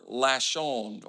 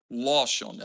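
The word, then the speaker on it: loshon (לשון): The word tongue comes from the Hebrew word lashon, or loshon is the pronunciation.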